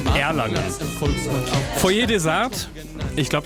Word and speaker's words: Erlangen: Auf jeden Fall Erlangen, und das hat auch Dimi geschrieben, der sagt, er kommt aus Erlangen, Betonung aufs erste E.